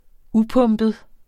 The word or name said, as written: upumpet